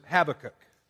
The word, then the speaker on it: Habakkuk: Now, some folks pronounce it Habakkuk, other folks pronounce it Habakkuk.